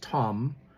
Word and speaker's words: tam: Well, there's only one person who's really perfect, and that was Jesus Christ, but we're talking about a King James translation of the word tam, or pronounced tom, and it means morally, pious, specifically, gentle, dear, humble.